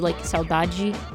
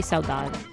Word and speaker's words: saudade: So, in Brazil, especially in Rio, they pronounce, the D-E as a J, so, saudade, that's how you would say it, in Brazil, but, in Cape Verde, they would just say saudade.